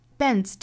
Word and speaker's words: bends: Bends 这个词后面加的这个 s 啊，应该是读 z 的发音，所以 d 和 z 连在一起应该是读 z。